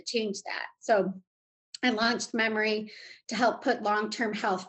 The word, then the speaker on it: Memore: It's M-E-M-O-R-E, but it's pronounced as Memory.